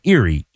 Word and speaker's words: Erie: Erie Home is spelled like the Pennsylvania town of Erie, E-R-I-E.